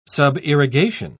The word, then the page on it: subirrigation: British English